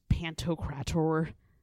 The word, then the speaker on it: Pantocrator: Well, okay, so it's sort of like, you know how there is like Christ Pantocrator. – Did you say that right? – I always have to say it like that. Yeah, look it up. That's exactly how you say it.